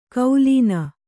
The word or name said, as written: ಕೌಲೀನ